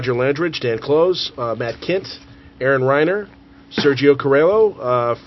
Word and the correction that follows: Carrillo: Did I say it wrong, Sergio? – It's like Latin. – Carrillo? – Carrillo? – Yeah, there you go.